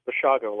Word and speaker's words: Bashago: It's Bashago, like Chicago.